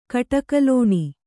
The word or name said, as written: ಕಟಕಲೋಣಿ